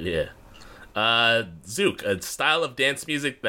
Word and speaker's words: Zouk: Zook. – Yeah. – Zook.